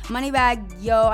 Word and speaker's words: Moneybagg Yo: Talk to me about Moneybagg Yo. I'm not even gonna front for the podcast listeners. Moneybagg Yo, and am I saying it right? – Yeah.